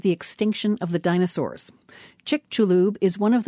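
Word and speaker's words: Chicxulub: Chick-chu-lub.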